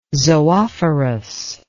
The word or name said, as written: zoophorus